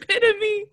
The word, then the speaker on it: epitome: Did you just say epitome instead of epitome? No. – Is that not how you say it? – It's epitome. Oh my god. It is too early. – Why is it spelled like epitome? – How the fuck are you getting a PhD?